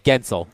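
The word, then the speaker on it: Guentzel: You don't even pronounce it. There's no sounding of the U in there at all. – No. – Right. – It's Gensel. – Yep. – G-I-N-Zil. – Yep. – That's how I would spell it. – Sometimes I write it down like it sounds. Okay. Down like it's spelled. – It rhymes with pencil.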